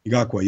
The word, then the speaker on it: Ngakoue: What, Ngakoue, yeah.